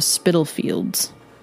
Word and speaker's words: Spitalfields: Okay, so the second victim was Annie Chapman, and she was murdered in Hanbury Street of Spitalfields. I hope I said that right. – Yeah, that's right.